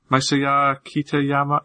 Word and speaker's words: Masaya Kitayama: You sure it's in? – Masaya Kitayama? Yeah, I'm sure.